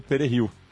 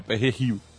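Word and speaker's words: perejil: eles pediam que a pessoa falasse Pererrio, E o francês não consegue falar com a mesma pronúncia. Ele fala Perreril.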